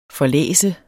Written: forlæst

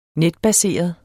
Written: netbaseret

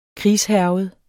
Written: krigshærget

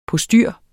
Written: postyr

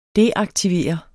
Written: deaktivere

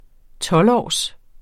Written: tolvårs

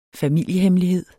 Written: familiehemmelighed

